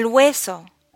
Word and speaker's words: hueso: Bone El hueso. Words like hueso and huevo begin with HU, and the HU is pronounced like a W. Hueso.